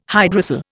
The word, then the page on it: hydrocele: Indian